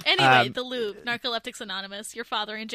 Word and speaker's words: Louvre: With the caption, Narcoleptics Anonymous met in the Louvre today. Or is that the museum? And I don't know how to pronounce it in French. – The Louvre, yeah.